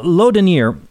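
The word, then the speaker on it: Laudonnière: Well, 2 years later, a man named Laudonniere, and I'll spell that, L-A-U-D-O-N-N-I-E-R-E, Laudonniere, built a fort on the St. John's River in Florida.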